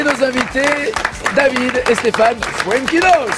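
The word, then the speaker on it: Foenkinos: Déjà, on va dès le début, tout de suite, enlever une vanne que j'ai subie hier, honteusement, parce que j'ai dit Fuenquinos, et vous avez dit, mais non, ça se prononce pas comme ça, les garçons. – Tu le dis parfaitement. – Bah voilà, c'est la bonne prononciation.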